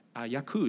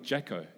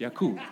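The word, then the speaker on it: Jaco: Like Yaku is not Jacko. It's Yaku.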